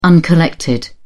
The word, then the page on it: uncollected: British English